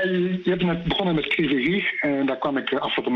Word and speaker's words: Kryvyi Rih: Vanuit Oekraïne even, jij zit nu in Kryvyi Rih, zeg ik het goed? – Ja, dat zeg ik uitstekend.